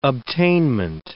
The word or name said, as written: obtainment